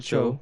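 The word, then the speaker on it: Choe: So yeah, if you guys don't know, how do you say his last name? – David Cho.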